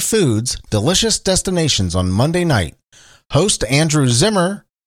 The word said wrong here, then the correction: Zimmern: Zimmern, is that how you say it? I always thought it was Zimmerman. Andrew Zimmern will take a tour of Cincinnati's culinary icons on the upcoming episode.